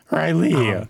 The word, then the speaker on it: Riley: Riley. Emphasis on the second syllable.